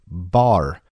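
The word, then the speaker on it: bar (בר): And the Masoretic scribes believed that B-R was intended to have the A vowel sound and thus was the word bar.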